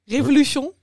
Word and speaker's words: Revolution: Ja, hoe spreek jij Revolution uit? – Revolution. – Revolution, oké.